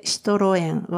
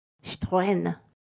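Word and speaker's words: Citroën: ですので、シトロエンはフランス語での元々の発音ですと、シトエン、シトエンになります。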